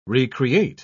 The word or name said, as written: re-create